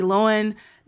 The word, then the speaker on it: Lohan: Hilary Duff, Lindsay Lohan, Natalie Portman, Emma Watson, and the list unfortunately just keeps going and going. – I really respect your proper pronunciation of Lohan.